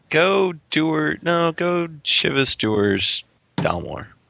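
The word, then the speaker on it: Dewar's: Dewars.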